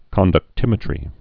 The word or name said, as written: conductimetry's